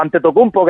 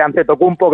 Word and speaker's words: Antetokounmpo: Giannis Antetokounmpo o Antetokounmpo, que es la pronunciación más americanizada, por así decirlo. Él, por explicárselo un poco a la gente, Antetokounmpo es el apellido nigeriano, pero claro, al transliterarlo al griego, pues sale una especie de Antetokounmpo, que es como la mayoría le llamamos, ¿no? Ajá. Pero realmente la pronunciación es Antetokounmpo.